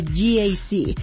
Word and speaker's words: GAC: Se escribe GAC, pero se pronuncia GAC.